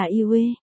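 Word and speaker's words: Yue (月): tên Nguyệt và Việt trong tiếng Trung đều đọc là Yue.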